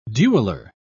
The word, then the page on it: dueler: British English